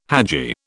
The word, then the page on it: hadji: British English